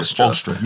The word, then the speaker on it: Spoelstra: I think Spoelstra. You got Spoelstra, yes.